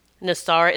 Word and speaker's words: NESARA: Nasara is spelled N-E-S-A-R-A. Nasara Mission by James Rink, R-I-N-K.